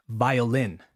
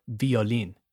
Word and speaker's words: violin: Violin never violin. It's violin. Trumpet.